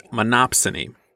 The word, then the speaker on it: monopsony: SAT word for this episode is monopsony. – Did you say that right? – Yeah.